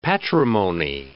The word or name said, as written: patrimony